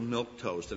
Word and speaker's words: Milquetoast: This is Casper Milquetoast. And it's interesting, the way it's spelled in the cartoon is almost like a French name, M-I-L-Q-U-E, toast.